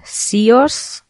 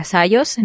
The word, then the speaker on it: Syoss: Y yo de hecho ahora uso una marca que se llama Sios, S Y O S S. Creo que se pronuncia Sayos en realidad